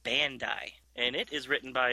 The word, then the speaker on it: Bandai: And there's a there's a toy company called Bandai, and it's spelt that way. It's a Japanese toy company, so I just assume that's how you would pronounce it.